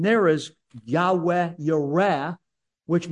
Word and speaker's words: Yahweh: And most Semitic scholars believe it's pronounced a breathy Yahweh.